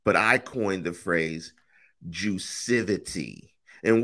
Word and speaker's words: Juicivity: And it's pronounced Juicivity.